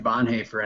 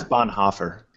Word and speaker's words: Bonhoeffer: But it was this cost of discipleship, to kind of talk about Dietrich Bonhoeffer and his language. – Actually, it's Bonhoeffer. – Oh, sorry, Bonhoeffer.